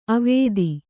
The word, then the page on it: ಅವತ್ತು: Kannada